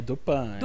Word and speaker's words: Dupin: É Dupin ou Dupin? Eu não sei pronunciar francês, gente. – É Dupin. – Dupin. É tipo Lupin. Dupin. Né, não, Lupin.